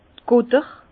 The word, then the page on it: d' Gutter: Bas-Rhin; Herrlisheim